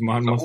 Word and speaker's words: Monmouth: Or his family moved to New Jersey, and he played his senior year in New Jersey, and his only offer was Monmouth. I don't even know if I'm pronouncing that right. – It's Monmouth.